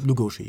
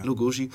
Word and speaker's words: Lugosi: Ja, wie, wie gesagt, dieser Bella Lugosi oder wie, wie spricht man richtig aus? Lugosi, ja. Lugosi.